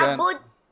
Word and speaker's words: نعبد: ضم الشفتين عند النطق بالدال، ضم الدال. إياك نعبد، إياك نعبد وإياك نستعين، اهدنا الصراط المستقيم، صراط الذين أنعمت عليهم غير المغضوب عليهم ولا الضالين.